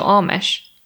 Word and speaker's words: Amish: Amish or Amish? – it's Amish. I did check the ah.